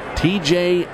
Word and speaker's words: Tejay: It's not the initials. His name is spelled T-E-J-A-Y. TJ Antone.